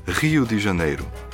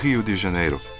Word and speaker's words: Rio de Janeiro: A lahko zavrtiš nazaj, pa počasi Rio de Janeiro izgovarjava. To je brazilski R, še posebej, če je na začetku besede je bolj podoben črki H, v Rio de Janeiro, poje Gilberto Žil.